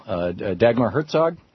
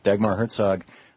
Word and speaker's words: Dagmar Herzog: Dagmar Herzog, am I pronouncing it right? – Yes. – Yeah, Dagmar Herzog.